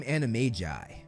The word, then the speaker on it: Animagi: and they became animagi. And yes, that's how you say it. According to Rowling, Animagus or Animagi is pronounced with a J, not a G.